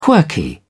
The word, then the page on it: quirky: British English